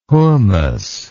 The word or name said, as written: hommos